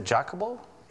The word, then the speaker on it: Jacobo: Where many of the Spanish Bibles have the Epistle of Santiago, but the 1602 Purified has it, the Epistle of, how do you say that, Jacobo? Okay, there you go, he got it.